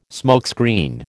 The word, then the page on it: smokescreen: American English